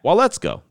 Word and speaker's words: Waletzko: I might have said his name wrong. Hold on, let me check. I got a little guide here. Let me check. No, I said his name right. Matt Waletzko.